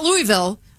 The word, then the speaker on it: Louisville: Not Louisville. – You got to say it like, peanut butter stuck in the roof of your mouth. Louisville.